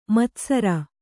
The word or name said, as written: ಮುತ್ತುಸಾರೆ